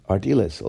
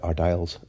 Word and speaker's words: Ardiles: would be Ardiles, although we sometimes pronounced it Ardiles at the time.